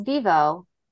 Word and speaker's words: Vevo: Um, is it pronounced Vevo? – Yep, you got it.